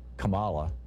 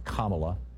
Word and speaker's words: Kamala: Interestingly, the song pronounced it Kamala, but I think it's actually pronounced Kamala, like calm Allah.